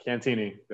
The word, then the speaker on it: Cantini: Yeah, Cantini.